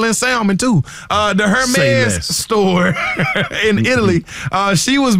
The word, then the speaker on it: Hermes: Savannah James was on a podcast the other day, and she was speaking about her experience at a Hermes store in Italy. Is that how you see? I always hit the H. – H is silent.